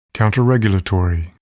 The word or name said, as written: counterregulatory